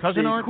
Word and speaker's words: CuisinArt: Michael Masci is here with us, director of entertainment for the, pronounce the name of the golf resort. Is it CusinArt? – CusinArt.